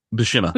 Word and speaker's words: Buscema: Buscema.